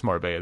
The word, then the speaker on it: Marbella: And I am pronouncing that correct, right? It is Marbella, the double L. – Yeah, you got it.